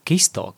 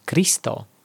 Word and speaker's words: Kristo: Viņš iegāja bufetē paēst, kad izdzirda, ka bufetes saimnieka dēlēns bieži šļupst kisto, kas nozīmē kristo, svēto Kristus vārdu. Panāc šur bērniņi, Basko pamāja – Vai gribi, es tev iemācīšu izrunāt šo vārdu? Lūk tā, noņem cepurīti un saki Kristo, nevis Kisto.